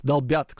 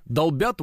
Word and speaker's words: долбят: Долбит Wildberries. Долбят, кстати, правильное ударение. Долбят Wildberries.